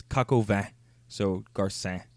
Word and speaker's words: Garcin: Joseph Gar- Garcine? – Garcin. – Garcin. Garcin.